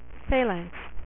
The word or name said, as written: Phalanx